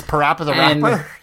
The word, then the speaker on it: Parappa the Rapper: Like it's spelled like PP Rapper, but it's pronounced Papa Rapper. – He's Parappa the Rapper? – Yeah, he's Parappa the Rapper.